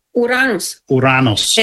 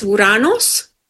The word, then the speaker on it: Uranus: How do you say it properly in like Latin? – Uranus. And in Greek, it's Uranos.